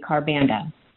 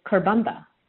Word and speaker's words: Karbanda: Some people like to say Karbanda, but traditionally, it is Karbanda. I sometimes pronounce my full name as Anita Jari Karbanda, which is fine as well.